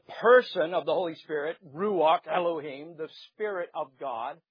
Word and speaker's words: Ruach: The spirit of creation, if you're following along your outline, is called the Ruach Elohim. The Ruach Elohim, and Ruach is spelled R-U-A-C-K.